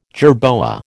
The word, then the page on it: jerboa: American English